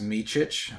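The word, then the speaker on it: Micić: And uh, it, his his last name is pronounced Micic.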